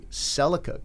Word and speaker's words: Celica: Even brand names like Celica. – Yeah. – And when you watch a Celica commercial up here in Canada, they just change the pronunciation to Celica.